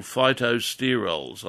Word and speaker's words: phytosterols: And these are things that are called phytosterols. I'll spell it for you. P-H-Y-T-O, phyto, sterol, S-T-E-R-O-L-S, phytosterols.